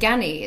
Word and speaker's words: Ganni: You know, like I think Ganni as well.